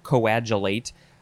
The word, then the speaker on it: coagulate: And you were probably all like, oh my God, why doesn't he know how to say coagulate? It's not coagulate.